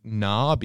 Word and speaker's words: Knarr: or I'm saying Knarr because in like modern English, we silence the K, but I've got no idea how the Vikings pronounced it. It's spelt K-N-A-R-R.